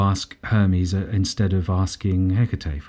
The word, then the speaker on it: Hecate: Hecate.